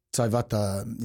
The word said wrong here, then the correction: Taivanawai: you know, I can't pronounce his name. Taivanawai, sorry. – Yeah, that's it.